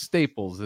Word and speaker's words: Staples: And I got, it's just Staples, is it right, Josh? – Yeah, you just Josh pronounce Staples. I'm always looking. – You think I'm Dutch? What is this? – Stapalis, dude. – Staples. – Yeah, right.